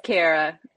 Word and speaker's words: Cara: It's Cara.